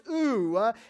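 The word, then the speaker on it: ooh (οὐ): That word not, it is actually O-U. It is spelled or it is pronounced ooh, and it actually speaks of a full and a direct negation.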